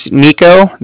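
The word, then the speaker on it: Niko: My name's Nico. That's N-I-K-O. – Mm-hmm. – And then I'm calling from Cardiff. That's spelled C-A-R-D-I-F-F, as in Frank.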